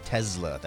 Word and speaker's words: Tesla: Looking at the shares of Tesla, and that's how you pronounce it, Matt. Tesla. That's how Elon Musk pronounces it.